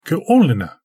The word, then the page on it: g′olena: Galindri